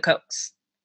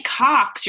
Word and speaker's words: Koch: The Cokes? K-O-C-H. You can pronounce that a lot of ways. K-O-C-H. Sure. – No, it's true. Because if you say Cox, you're like, that's the Coke.